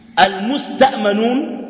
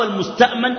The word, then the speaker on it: المستأمن: أما المستأمنون أو المستأمن أو المستأمن، المستأمن يعني حد الأمان، المستأمن هو الطلب شنو؟ الأمان. وكلا النطقين شنو؟ صحيح، المستأمن والمستأمن.